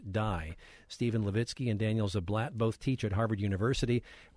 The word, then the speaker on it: Ziblatt: Daniel Ziblatt, thanks for joining us.